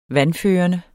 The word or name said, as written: vandførende